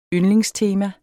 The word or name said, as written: yndlingstema